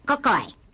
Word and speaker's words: Kokoi: K-O-K-O-I. Pronounced Cocoy.